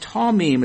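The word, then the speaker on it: tamim (תמים): The Hebrew word here is tamim. It's pronounced tamim. It's spelled T-A-M-I-Y-M.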